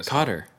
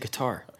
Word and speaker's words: Qatar: Why do we always say Qatar? – I don't know why. I think it was probably just like people mispronouncing it on the news originally.